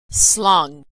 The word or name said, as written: slung